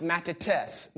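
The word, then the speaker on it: matetes (μαθητής): It's a Greek word that is pronounced matetes, matetes.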